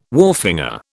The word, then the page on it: wharfinger: British English